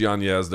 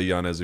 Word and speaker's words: Yanez: Rob Font defeating Adrian Yanez. Yes, it is pronounced Yanez. There is an Enye over the N. He doesn't pronounce it that way. You can say Yanez if you want.